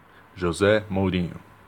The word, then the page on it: José Mourinho: ptbr